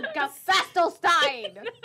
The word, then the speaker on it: Gesaffelstein: Is gesaffelstein going to play? – No, gesaffelstein. His name. Not gonna have you disrespecting him like that, saying his name wrong.